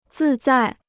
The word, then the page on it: 自在: zì zai